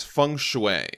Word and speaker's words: feng shui: I wouldn't call myself an authority, but the pronunciation for feng shui that I've always heard is feng shui.